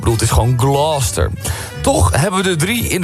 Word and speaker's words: Gloucester: De Engelse plaatsnaam Gloucester spreek je uit als Gloucester. – Gloucester. Ja. Gloucester. Als één vloeiend woord, Gloucester.